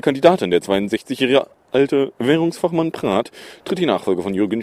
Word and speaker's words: Praet: Am Dienstag, nicht müssen, Überraschung bei der EZB, der Belgier Peter Praat, geschrieben Praet, aber ich habe ihn inzwischen ausgesprochen gehört, wird nämlich neuer Chefsvolkswirt der Europäischen Zentralbank.